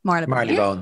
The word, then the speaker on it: Marylebone: Anyways, it was in Marylebone. Is it Marylebone? I always get nervous pronouncing it. It's Marylebone? – Marylebone. Yeah. – Marylebone. – You say the bone.